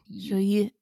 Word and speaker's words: Joyeux: The end is like almost like a U. – Joyeux.